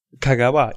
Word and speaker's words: Kagawa: Ja, es heißt ja auch Kagawa. Ja. – Kagawa, ja.